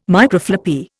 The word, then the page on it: Microfloppy: Indian